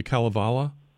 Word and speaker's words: Kalevala: the Kalevala? – Yeah.